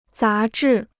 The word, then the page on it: 杂志: zá zhì